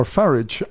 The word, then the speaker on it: Farage: Nigel Farage, or Farage, as he's actually should be pronounced, has decided to go north of the border.